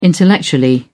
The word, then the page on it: intellectually: American English